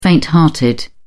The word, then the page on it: fainthearted: British English